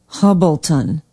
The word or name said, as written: hubbleton